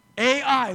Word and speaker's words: Ai: Ai, literally, that's how you spell it, Ai. And that's how you say it.